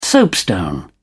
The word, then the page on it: soapstone: British English